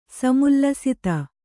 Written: ಸಮುಲ್ಲಸಿತ

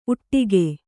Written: ಊಟಗ

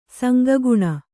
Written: ಸಂಗಗುಣ

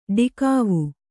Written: ಡಿಕಾವು

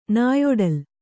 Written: ನಾಯೊಡಲ್

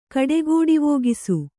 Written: ಕಡೆಗೋಡಿವೋಗಿಸು

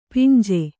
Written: ಪಿಂಜೆ

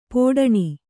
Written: ಪಡಣ

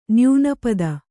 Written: ನ್ಯೂನಪದ

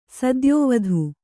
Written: ಸತ್ಯವತಿ

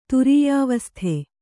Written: ತುರೀಯಾವಸ್ಥೆ

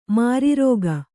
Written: ಮಾರಿರೋಗ

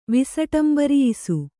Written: ವಿಸಟಂಬರಿಯಿಸು

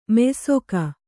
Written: ಮೆಯ್ಸೊಗ

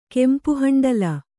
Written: ಕೆಂಪುಹಂಡಲ

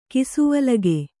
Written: ಕಿಸುವಲಗೆ

